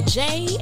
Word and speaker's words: Jaie: This is your girl, Jay, and welcome to the J-Spot, and that J is spelled J-A-I-E.